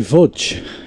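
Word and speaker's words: Łódź: It's like Vudge. – Or Norwich. – Do you know where Vudge is? – No idea. – It's where my family's from. – Oh, right. – In Poland. It's spelt L-O-D-S.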